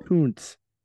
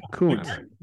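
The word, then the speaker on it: Koontz: A-O-O-N-T-S. Kuntz. – Oh, Kuntz. – Yeah. – Yeah, Kuntz. – Like Art Kuntz. – Yeah. – Yeah. – Yeah. – That's just gross. – Doesn't Kuntz just mean art in German?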